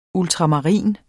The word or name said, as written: ultramarin